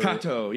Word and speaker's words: Kato: Or Cato, yes. Whatever. But it's spelled K-A-T-O.